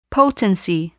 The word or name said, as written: potency